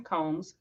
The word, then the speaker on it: Combs: Well, hey, guys, my name is Sharon Combs. Sharon is spelled with a Y, S-H-A-R-Y-N. Combs, like you would comb your hair or like a comb to comb your hair, but plural.